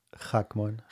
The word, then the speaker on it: Hackman: I wouldn't be offended because the way I say it in Hebrew is different than the way I say it in English. Which one of them? – Say your name, how you say it, like how it's supposed to be said. – Hackman.